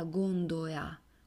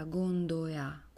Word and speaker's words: gondoea: La gondola in veneziano è chiamata gondoea, quindi noi abbiamo questa pronuncia della L poco marcata, gondoea, quasi non si sente.